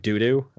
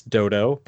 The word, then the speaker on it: dodo: And she's like, wait, do I go to this airport named Doodoo? I'm like, it's Dodo, but yes. She's like, no, how do you spell do? I'm like, D-O. Then why is it do? Because it's a bird, so it's pronounced do-do. – And that doo-doo is spelled D-O-O-D-O-O.